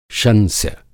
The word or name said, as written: शंस्य